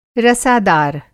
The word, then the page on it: रसादार: Hindi